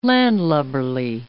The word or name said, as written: landlubberly